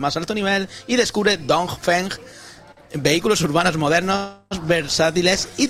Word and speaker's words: Dongfeng: Dongfeng. – XPeng y Dongfeng. – Dongfeng. – Es que si pronuncia mucho la G, al final lo liamos.